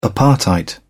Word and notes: apartheid: British English

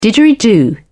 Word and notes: didgeridoo: British English

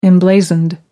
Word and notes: emblazoned: British English

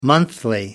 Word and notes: monthly: American English

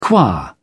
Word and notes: qua: British English

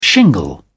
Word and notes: shingle: British English

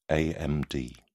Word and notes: amd: British English

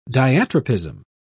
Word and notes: diatropism: British English

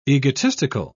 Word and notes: egotistical: British English

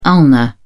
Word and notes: ulna: British English

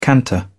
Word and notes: canter: British English

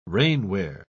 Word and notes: rainwear: British English

